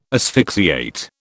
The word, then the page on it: asphyxiate: British English